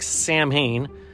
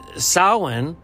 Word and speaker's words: Samhain: And if you're going to read it or spell it, it looks like Samhain, S-A-M-H-A-I-N, I believe, but it's pronounced Samhain.